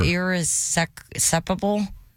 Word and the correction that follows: irreconcilable: Hmm. – Still not. – It's irreconcilable differences. – Irreconcilable.